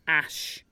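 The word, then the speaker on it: Æ: the like number that's attached to each other is actually a Nordic ligature pronounced Ash. So the kid's called Ash, but in a fancy way.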